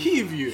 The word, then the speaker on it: Hivju: Så har vi Kristoffer, hur man nu än uttalar hans efternamn. Hivju! Hivju! Hivju.